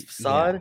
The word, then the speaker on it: facade: I think I said that right. It's facade. – Facade? It's facade. Yeah. – I'm pretty sure. – I used to say facade, and then people said, no, it's facade.